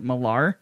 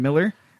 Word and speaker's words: Millar: "It's Miller Time," but it was spelled Millar, and then they even mentioned in the article, yes, it's pronounced like the beer.